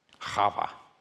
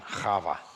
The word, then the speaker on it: Chava: Does anybody remember the Hebrew pronunciation for Eve? Chava. Say that one, Chava. You'll always know you say it right if there's a little piece of phlegm on the person in front of you right there.